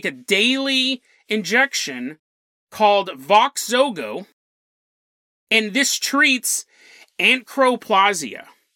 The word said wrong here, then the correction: achondroplasia: This treats achondroplasia.